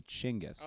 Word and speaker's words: Chinggis: I did say Genghis. Genghis Krist. – Apparently, in the building. – Apparently, it's actually Chinggis. – Oh, yeah. – Chinggis Krist. – Yeah, that's how you pronounce it.